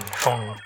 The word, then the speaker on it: Phaune: I mean, phone radio. It's spelled P-H-A-U-N-E, but it's pronounced phone.